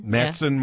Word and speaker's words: Matteson: Well, that's where he used to, like the first time I saw him play here, he played like at the how do you say it, Madison? – Matteson Mall.